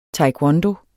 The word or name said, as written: taekwondo